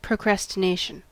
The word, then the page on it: procrastination: American English